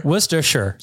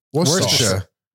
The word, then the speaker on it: Worcestershire: It sounds like you're re-pronouncing the word in the middle of the word. – Worcestershire. – It's like you go to say the wrong thing, you say Worcester, and then you're sure.